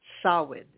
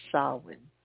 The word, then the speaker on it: Samhain: Samhain is how you pronounce it. Samhain.